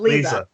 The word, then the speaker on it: Leza: I almost said Liza. Yeah, that is Liza. – No, it's Lisa.